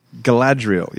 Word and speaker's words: Galadriel: Galadriel.